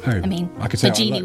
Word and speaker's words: Baggini: I think he's British, so Baggini would be fine.